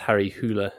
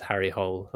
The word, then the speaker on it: Harry Hole: He plays the titular character, which I believe it is pronounced Harry Hula in the original language, but it just reads as Harry Hole.